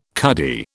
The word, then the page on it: cuddy: British English